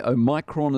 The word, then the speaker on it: Omicron: Omicron is how the classicists pronounce.